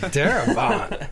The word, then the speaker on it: Darabont: And her boss, I don't know if you guys have ever heard of this guy, Frank Darabont, is that how you say it? – Frank Darabont.